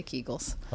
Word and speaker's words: Kegels: Actually, the gynecologist who first described that passed away many, many years ago, so I'm not sure how he pronounced his last name, but we usually say Kegels.